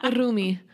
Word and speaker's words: Rumi: I thought it was like R-O-O-M-I-E. – Oh, I don't know. How would you pronounce Rumi? – Rumi. Just roll the R.